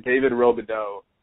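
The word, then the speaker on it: David Robidoux: I have a library in my iTunes full of I believe I'm pronouncing this correctly, David Robideau. He's amazing. Yes, yeah, you are pronouncing it correctly.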